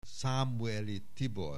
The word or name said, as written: Szamuely Tibor